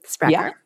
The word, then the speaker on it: Sprakkar: Did I pronounce that right? – SPRAKKAR. Yeah.